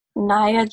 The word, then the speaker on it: Nyah: Well, on TikTok, my username is Naya Jones with an underscore after it. That's spelled N-Y-A-H Jones with an underscore.